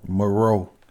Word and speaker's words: Mereaux: That was uh Maya Monroe. Moreau. I'm gonna spell it for y'all, man. It's uh M A Y A M E R E A U X.